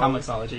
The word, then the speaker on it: Comixology: Let's say it right, Comixology. – Comixology, yeah.